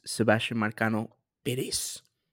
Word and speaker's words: Sebastian Marcano-Perez: My name is Sebastian Marcano-Perez.